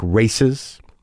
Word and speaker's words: RAICES: It was set up by a group called RAICES. That's RAICES, Texas, Refugee and Immigrant Center for Education and Legal Services. It's pronounced like races.